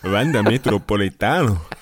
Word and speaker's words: Wanda Metropolitano: Wanda Metropolitano. So wird es ausgesprochen.